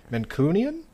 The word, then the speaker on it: Mancunian: I've been to Manchester, and I don't know how to pronounce this. It's apparently the term for people who live in Manchester. No, Mancunian? Mancunian.